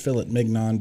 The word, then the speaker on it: filet mignon: It's pronounced filet mignon, but yeah. – Don't use the hard N.